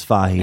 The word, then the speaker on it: Fahey: In Ireland, it's Fahey.